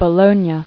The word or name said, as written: Bologna